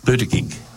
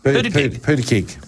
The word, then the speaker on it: Buttigieg: Do you want me to pronounce it for you? – Buttigieg. – Buttigieg. – Yeah.